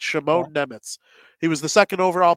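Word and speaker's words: Šimon Nemec: One thing I wanted to talk about quickly, Simone, we got confirmation on how to say his name. It's Shimon Nemitz. So that's how I'm going to be saying it from now on. – Shimon Nemitz? – Yeah. Like Shimon, and then N-E-M-E-T-Z is how you pronounce it.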